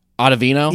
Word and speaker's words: Ottavino: I don't even know how to say this dude's name. Ottavino? – Yeah, it sounds right.